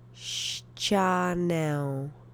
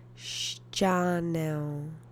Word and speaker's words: ścianę: The accent is on the first syllable. Ścianę. Ścianę. Ścianę.